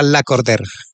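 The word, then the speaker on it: Lacordaire: Es que es un nombre francés, sí, se escribe la cordaire, pero se Ajá. – pronuncia la corder.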